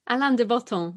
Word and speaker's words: Alain de Botton: How do you pronounce his name? – Alain de Botton, yeah.